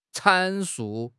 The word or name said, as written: 阐述